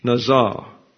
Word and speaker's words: nazah: The meaning of nazah, now this is spelled N-A-Z-A-H, nazah.